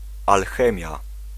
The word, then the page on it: scheikunde: /ˈsxɛi̯.kʏn.də/